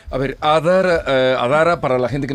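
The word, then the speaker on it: Adhara: Adara se escribe con H detrás de la D, Adara y eh, allí pues podemos encontrar, eh, dedicamos eh, parte de los servicios a la prevención, como a la parte educativa.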